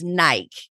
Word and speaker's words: Nike: I said Nike. And she was like, no, girl, it's Nike. And she's like, I live in the Pacific Northwest. That's where they're headquartered. That's not how you say it.